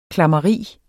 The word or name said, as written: klammeri